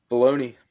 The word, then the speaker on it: Filoni: Filoni.